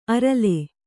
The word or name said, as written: ಆರೆಲು